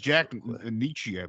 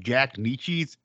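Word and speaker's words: Nitzsche: It's Jack Nietzsche, I believe is how you say it, pronounce his name. – Say it again. – Jack Nietzsche, NITZSECHE.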